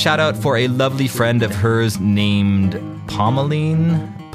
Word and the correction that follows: Pomeline: Pumlin, sorry. There's always a pronunciation.